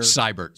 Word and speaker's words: Seibert: Siebert had been their Seibert. – Seibert.